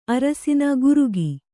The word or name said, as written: ಅರಸಿನ ಗುರುಗಿ